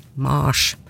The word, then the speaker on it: Mars: Og i latin så ble navnet til krigsguden Mars uttalt, nettopp med lang A, Mars.